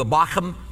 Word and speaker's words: Bochim: Then the angel of the Lord came to Gilgal at Bochim and said, that's how that's really pronounced, by the way. In Hebrew, the Bochim has that kind of in it. It's kind of the spit noise.